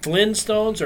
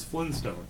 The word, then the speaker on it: Flintstones: Yeah, I have seen that one too, where it's like, oh, it's Flintstones. No, you just, most people don't pronounce the T.